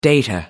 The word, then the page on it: data: American English